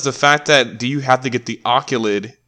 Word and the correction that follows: OLED: That's how you pronounce it? – The what? Sorry. – The Oculid, the, you know, the new Switch. – Oh, OLED. Yeah, OLED.